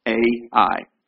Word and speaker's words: Ai: Ai. Ai is how you pronounce it.